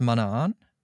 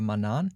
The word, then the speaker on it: Manaan: Planeten, der heißt Manaan oder Manan, je nachdem, wie man es aussprechen möchte.